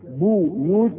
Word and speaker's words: بيوت: كلمة دي جاء، فمثلا كلمة بيوت يقول لك قرأت بالضم وقرأت بالكاف، بو يوت وبي يوت، تنطق كده وتنطق كده.